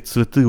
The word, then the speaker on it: цветы: اگه این ت رو درست نتونین تلفظ کنین شبیه میشه به اون سویتا، سویت، سویت میشه نور، цветы میشه گل ها، цветы، цветы. اون ای آخر دوباره از اون ای های غلیظه، цвет، цветы، цветы.